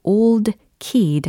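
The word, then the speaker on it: ole: 여기에서 이제 가사를 만약에 직접 눈으로 확인을 하신다면, O-L-E, 예, apostrophe kid. 이렇게 쓰여져 있거든요. 어, 발음상은 마치 old kid 하고 거의 동일합니다. old kid, old kid 이렇게 하잖아요.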